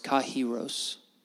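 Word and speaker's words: kairos (καιρός): It is, the Greek word time is, we would probably say it kairos. It's actually pronounced kairos.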